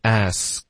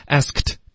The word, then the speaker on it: asked: asked, OK, asked, 就是 ask, 问了就加一个 ed, asked, kt, kt, 这个音特别痛苦 OK, 我发是没问题的，可是为何发呢所以就是一般老外说 asked, 的时候呢都会把这个科和特的音都省略，除非这个特要连读到后面的音，那就只省略科，然后就发 s, 就这样。